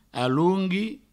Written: alongui

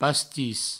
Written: pastis

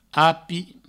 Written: api

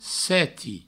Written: seti

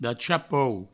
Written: d'a cha pauc

